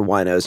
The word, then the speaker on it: Wino: Its name is spelled W-I-N-O. – And so Wino.